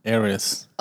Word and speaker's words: heiress: The richest lady in the USA is Iris. How do you say this word? – I knew you weren't going to know. Heiress.